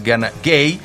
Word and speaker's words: Gana Gueye: Lamin Camara, porque también tiene su Lamin allá en Senegal, está en la media cancha junto con Gana Gueye, quiero decir, la pronunciación es Gana Gueye.